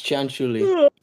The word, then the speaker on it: Cianciulli: Yeah, it's Cianciulli. – Cianciulli. – You try pronouncing it. – It's Italian. – It's Italian, so the C would be a ch sound.